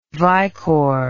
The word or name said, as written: Vycor